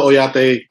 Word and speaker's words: Oyate: Introduce yourself if you would like and tell us a little bit about what you do at the Oyate group, and correct me if I'm not pronouncing it right. – No, Oyate, you're spot on with the pronunciation.